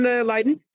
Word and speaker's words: Leiden: Back to the original question, which is, you mentioned Leiden.